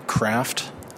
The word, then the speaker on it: Kraft: For the record, my name is Mark Kraft. It's spelled K-R-A-F-T.